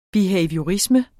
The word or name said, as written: behaviorisme